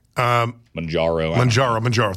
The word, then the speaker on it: Mounjaro: New York Post, blockbuster GLP-1 medications like Ozempic and Mounjaro, I think I pronounced that correctly. – Mounjaro, thank you.